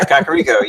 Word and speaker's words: Kakariko: Yeah, Kakariko, yeah.